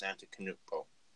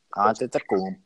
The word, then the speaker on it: Antetokounmpo: Currently, when LeBron James retires, you have Giannis Antetokounmpo. And, I just butchered that last name. Players in between Antetokounmpo.